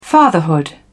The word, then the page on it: fatherhood: British English